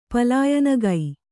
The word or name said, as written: ಪಲಾಯನಗೈ